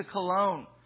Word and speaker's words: Cologne: Tyndall then in 1525 traveled to Cologne, that is how it's pronounced, with his, and he had an amanuensis, the guy who helped him write down these things, named William Roy.